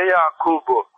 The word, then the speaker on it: Yakub'u: Ve Yakub'u, burada Yakup kelimesinin B harfi ötre okunuyor bizim rivayetlerimiz.